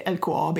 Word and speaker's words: LKAB: So the next step for LKAB, I'm going to say LKAB because that's how you pronounce it in Swedish.